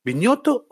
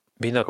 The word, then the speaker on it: Binotto: A mí lo de Hamilton, sobre todo más que, el, más que el comentario por parte de Viñoto, ¿Viñoto o Vinoto? ¿Cómo se dice? Vinoto. Como, o, sea, o sea, no es con ñ. No.